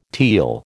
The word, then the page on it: teal: American English